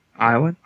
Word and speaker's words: Iowan: So hailing from Iowa, and he's a natural Iowan. Is that how you say it? – Uh-huh. – No, what is it? Is it? – Iowan? Yeah, it's Iowan. – Iowan.